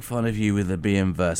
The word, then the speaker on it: BMW: Yeah, BMW. Because we've been. They pronounce their W, V. In the German language.